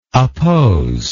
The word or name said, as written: apposable